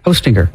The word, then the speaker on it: Hostinger: H-O, like Santa, ho, and then stinger. – Hostinger. – Like a bee has a stinger.